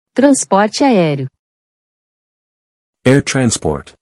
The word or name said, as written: Transporte aereo